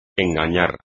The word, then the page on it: engañar: espagnol